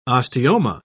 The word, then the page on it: osteoma: British English